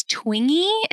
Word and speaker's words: Twenge: I think it's Twingy is how you say her last name. – Twingy. – T-W-E-N-G-E.